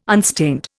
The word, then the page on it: unstained: Indian